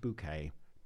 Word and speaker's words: Bucket: But Richard informs him that it's pronounced bouquet.